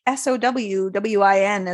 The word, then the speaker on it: Samhain: But it's a little bit of a tricky word because there's an M in there and it's the silent M. – Yeah, there's no W's either. Like, how are we getting Samhain to like Sowwin as a pronunciation?